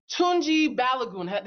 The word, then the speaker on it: Tunji Balogun: Tunji Balogun, did I pronounce that right? – Yes.